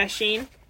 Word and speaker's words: Shein: I think it's pronounced Shein. – Shein.